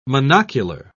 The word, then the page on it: monocular: British English